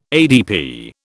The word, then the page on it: ADP: British English